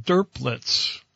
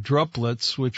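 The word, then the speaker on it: drupelet: And it's spelled just like droplet, but with a U, but they don't call them droplets like druplets, which is the way I was pronouncing it. So I went on Google and I wanted to make sure that I got the pronunciation, and they called them derplets, like it was D-U-R, not D-R-U.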